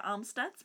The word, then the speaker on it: Amstutz: And you can find me at Sandra Amstutz.